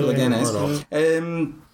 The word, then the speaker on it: Leganés: So basically, they got him from Leganés. Is that how it's pronounced? – Yeah, Leganés.